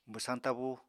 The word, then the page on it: bwsañ tabú: apatani